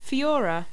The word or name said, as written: fyora